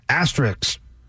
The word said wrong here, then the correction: asterisk: There's an S before the K.